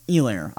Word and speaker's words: Edwards-Helaire: However, it was a very interesting game, in my opinion, because of the performance of Clyde Edwards Helaire or Elair. I forget the H is silent.